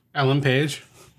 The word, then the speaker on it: Ellen Page: Ellen Page.